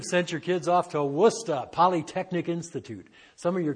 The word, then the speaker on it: Worcester: and they tell me it's spelled Worcester, but it's pronounced Worcester. And if you're from that part of the country, it's Worcester. Worcester Polytechnic Institute.